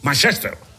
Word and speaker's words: Manchester: El United, el Manchester, con acento en la primera E. Manchester.